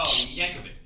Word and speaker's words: Yankovic: Oh, Yankovic, yeah.